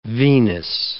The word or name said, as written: venous